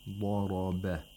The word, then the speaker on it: ضَرَبَ: ضَرَبَ. ضَرَبَ. The mouth opens vertically when pronouncing Dad and Ra, and horizontally while pronouncing Ba.